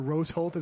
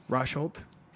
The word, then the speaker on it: Rosholt: So, your favorite one of the night, Roy Nelson versus Jared Rosholt, or Rose Holt, as I always call him, which is not the correct pronunciation of his Rosholt. – Name. Rosholt.